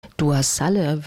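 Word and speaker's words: Dua Saleh: Dua Salle wird sie ausgesprochen.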